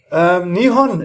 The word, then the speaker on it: Nihon: Nihon ist ja Japan. Manchmal auch Nippon ausgesprochen.